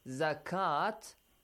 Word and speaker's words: zakat: When you see dagger alif on the top of waw or on the top of ya, so remember that it's not pronounced as waw or ya. Not wa, not ya. It will be changed to alif maddi. Look, for example, the word zakat.